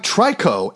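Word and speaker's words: Tricot: Up next, we have Trico, and apparently I have been saying their name wrong for years. You actually don't see the T at the end.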